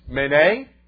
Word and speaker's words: Mene: The words are pronounced Mene, Mene, Tekel.